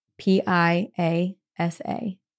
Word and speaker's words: Piasa: It was the Piassa, P-I-A-S-A, pronounced Pi-a-sa, which translates to the bird that devours man.